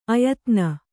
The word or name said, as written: ಆಯಿದೆನೆ